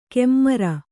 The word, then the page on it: ಕೆಮ್ಮರ: kemmara